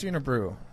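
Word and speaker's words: Unibroue: There's actually only one Canadian brewery I actually really love is the Unibrew. Unibrow, however you pronounce that. – I think it's Unibrew.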